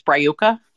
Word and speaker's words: Brayuka: Because I tried that and it's pronounced the first A is kind of silent, so it's Brayuka.